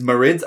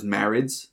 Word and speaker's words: Marids: Okay, second one I'm going to do is Marids. I always thought it was Marids, like marine. This is the water ones, but apparently it's pronounced Marids.